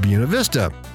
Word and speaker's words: Buena Vista: Buena Vista. I actually said that right, I think.